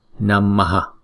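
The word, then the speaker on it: Namaha (नमः): Chữ H có dấu nặng này trong tiếng Phạn gọi là Visarga. Visarga này á, theo nguyên tắc thì chúng ta lấy cái nguyên âm ngoài trước của Visarga đó, chúng ta chuyển ra ngoài sau, chúng ta thêm vào đó mà chúng ta đọc, thì chữ này chúng ta đọc là Namaha.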